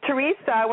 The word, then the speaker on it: Teresa: Like Teresa, when they would spell, some people would spell it with an H. I'm like, no, there's no H in my name. It's T-E-R-E-S-A.